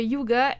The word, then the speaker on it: hygge: Ça s'appelle le yoga. – OK. – Et ça s'appelle H-Y-G-G-E. – Et non yoga. – Non, c'est ça, mais j'ai vraiment cherché la prononciation pour savoir yuga. Comment le dire.